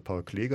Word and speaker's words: Klee: Paul Klee, wir haben viel mit den Kleinen natürlich viel mit Paul Klee gearbeitet, kannte man irgendwie, wobei die Kinder auch das richtig ausgesprochen haben, Paul Klee, und die Franzosen oft gesagt haben, wie, das heißt Paul Klee, na, das heißt doch Paul Klee, Paul Klee und aber wie gesagt, auch für die Eltern kam dabei durch diese Ausstellung eine ganze Menge an neuen Informationen.